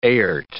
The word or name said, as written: airt